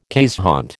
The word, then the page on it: keeshond: American English